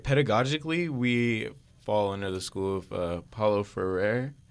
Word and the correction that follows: Freire: I'm butchering that name. – Freire. – Yeah, my Portuguese is trash.